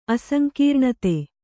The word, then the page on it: ಅಸಂಕೀರ್ಣತೆ: Kannada